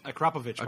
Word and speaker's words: Akrapovic: It's pronounced Akrapovic, by the way. Akrapovic. Nobody can ever pronounce it.